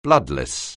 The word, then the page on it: bloodless: anglais